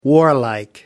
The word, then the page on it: warlike: American English